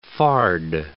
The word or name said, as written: fard